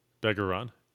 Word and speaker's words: Begarin: I think it's like Bergeron. Begarin.